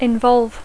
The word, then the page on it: involve: English